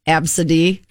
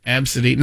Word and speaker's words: ABCDE: The name ABCDE, it's pronounced Absidy. – Absidy.